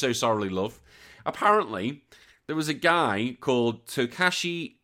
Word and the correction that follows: Takashi: Suzuki. Suzuki, sorry, Takashi Suzuki.